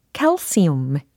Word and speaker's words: calcium: 칼슘 하고 발음이 확실히 다릅니다. 칼슘은 영어로 calcium 이렇게 발음해 주시면 되겠어요. 그것들은 엽산이 풍부해요.